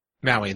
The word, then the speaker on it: Maui: Joining her on the adventure is her hero, the legendary demigod Mao. – Uh-oh. That's not a good name. – I'm probably saying it wrong. Maui, maybe? – Maui, that's it, Maui.